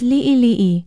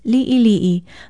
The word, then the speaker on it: li'ili'i: Be sure to include the diacritical pronunciation mark, the backwards apostrophe called the okina, and spell it L-I-Okina-I, L-I-Okina-I. It means small, little, in bits, or few. If you spell it right with the diacritical pronunciation marks, it is easier to pronounce li'ili'i.